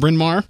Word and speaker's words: Bryn Mawr: Is that correct, Dave? Bryn Mawr? – That is correct. You even said it correctly.